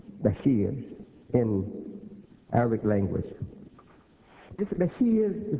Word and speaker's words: Bashir: B-A-S-H-I-R, they spell it, but it's pronounced B-A-S-H-E-E-R, Bashir.